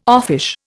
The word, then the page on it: offish: Indian